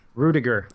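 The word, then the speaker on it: Ruettiger: It's a movie about Rudy Rutger, or how do we say his last name? – Rudiger. – I was so close.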